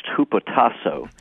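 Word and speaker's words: hupotasso (ὑποτάσσω): What it does mean, from the word, from the Greek word for submit, pronounced hupotasso,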